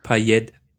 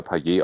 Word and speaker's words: Payet: Ja, also der Franzose an sich schwört Stein und Bein, dass er Paillet ausgesprochen wird, ohne T. Und dann gibt's die La Réunion Fraktion, die irgendwie sagt, ich habe ehrlich gesagt noch nicht mal eine Ahnung, wo La Réunion ist. und dort wird er wohl mit hartem T ausgesprochen, deswegen ist Paillet richtig.